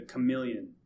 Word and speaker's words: chameleon: This one, the final one, the chameleon. This is probably the one that bugs me the most. – It's because it's spelled C-H. Chameleon. Yeah. Phonetically, it's a stupid word.